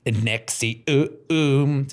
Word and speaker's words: NXIVM: Nexi, um, Se escribe N-X-I-V-M. Voy a llegar a porque se, porque se llama así. – Por mamar. Pero se pronuncia Nexium.